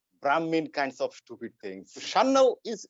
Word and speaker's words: Sanyal: Natürlich wird mein Nachname auch nicht so ausgesprochen, wie ich ihn mein Leben lang ausgesprochen habe. Also Sanyal. – You are Mitu Shannal. – Shannal.